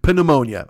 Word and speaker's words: pneumonia: it ruined the fucking pronunciation of the P. Pneumonia.